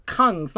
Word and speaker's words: Kungs: So Kungs, not Kings. – Kungs. – I was like, damn, Kings and David Guetta, that's huge. – That would be a crazy collab. – No, Kungs, David Guetta, and Izzy Bizu all night long, all on Global, on the TAHI list on Spotify.